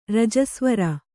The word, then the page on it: ರಜಸ್ವರ: rajasvara